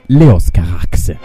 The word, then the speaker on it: Leos Carax: Anzi, si pronuncia così. Leos Carax.